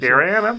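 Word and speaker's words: Garryana: Und das hier ist der Westland Gariana, wenn ich das richtig ausspreche. Korrekt, Gariana. Mhm.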